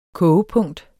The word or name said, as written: kogepunkt